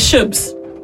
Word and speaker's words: shoobz: The word is shubs. In a sentence, I can put it if you ask me, but I'll spell it for you. S-H-O-O-B-Z. Shubs.